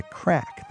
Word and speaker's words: craic: They're here to help us define what the craic, that's spelled C-R-A-I-C, is all about in Ireland.